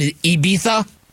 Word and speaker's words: Ibiza: So if Lamar Jackson, if Josh Allen, if Aaron Rodgers, if these dudes wanted to spend time in Ibiza this summer, you probably want to cancel those vacation plans.